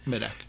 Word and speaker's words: Medak: Yes, Medak.